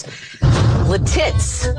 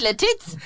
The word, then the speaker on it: Lititz: The great town names. Lititz. – No, it's Lititz.